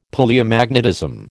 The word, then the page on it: Palaeomagnetism: American English